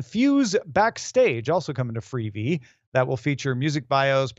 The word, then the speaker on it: Freevee: It's a smart time to rebrand from IMDb TV to two syllables, Freevee. – Freevee.